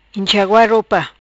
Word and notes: Inxagua Ropa: Patuá

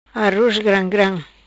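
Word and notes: Gram Gram Aroz: patua

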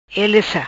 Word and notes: Ele sa: Patua

